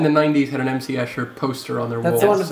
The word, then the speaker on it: Escher: I believe it's pronounced hammer. – No. – Is it M.C. Escher? Isn't yeah. – it? – Yes. – Yeah. Or was I making that up? – No, that's right.